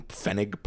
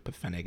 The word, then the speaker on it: pfennig: Originally, the limit was one pfennig per mass, which I'm pretty sure pfennig is how that's pronounced.